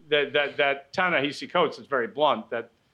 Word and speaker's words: Ta-Nehisi: I think he says Ta-Nehisi. I think he pronounces it Ta-Nehisi, notwithstanding the H-I-S-I. – All right, Ta-Nehisi.